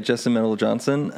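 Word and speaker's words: Justin Meldal-Johnsen: Justin Medell Johnson.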